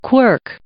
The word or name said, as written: quirk